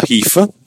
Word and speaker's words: HEIF: Ultimamente si sono, aggiunti, sono aggiunti nuovi formati come l'HEIF, di cui abbiamo parlato eh, circa un anno fa, si chiama Heath la pronuncia è quella, che consente invece di salvare informazioni con una minor perdita di qualità e con una profondità di colore fino a 10 bit.